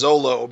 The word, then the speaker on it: Zoro: Zoro. – Who I pronounce Zolo because Zolo. – I've only ever read the comic.